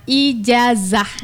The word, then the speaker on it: ijazah: Jadi keliru ketika dibilang ijajah. Nah, kita enggak menyalahkan karena mungkin di sini dialek bahasa Singkil. Jadi berpengaruh pada pengucapan bahasa Arab. Gitu ya. Nah, sebenarnya bukan ijajah. Ijazah.